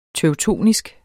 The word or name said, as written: teutonsk